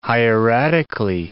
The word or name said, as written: hieratically